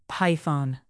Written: python

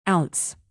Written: ounce

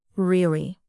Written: really

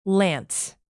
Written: lance

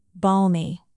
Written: balmy

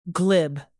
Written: glib

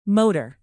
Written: motor

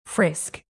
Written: frisk

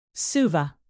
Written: suva

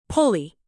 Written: pulley

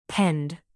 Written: pend

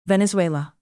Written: venezuela

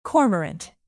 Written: cormorant

